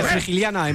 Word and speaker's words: Frigiliana: Frigiliana en Málaga, sí. – Sí, lo he dicho bien.